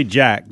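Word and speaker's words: Jak: Is it Jake or Jack? – Jack? Is it Jack? I'm sorry. I think you're right. No, you're right. It's J-A-K. – I just don't know how you pronounce it. – I misspoke. It's Jack. – That'd be Jack, just like Dax, D-A-K. – Yeah, it's Jack.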